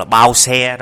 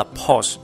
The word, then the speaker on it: pause: thường thường mọi người hay đọc là bao xe đó nhưng mà thực sự đọc đúng là pause